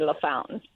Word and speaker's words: LaFontaine: It's spelled like LaFontaine, but we say LaFountain.